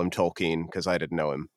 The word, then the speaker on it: Tolkien: Yes, it's Tolkien is how it goes.